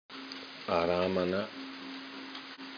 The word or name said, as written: arammana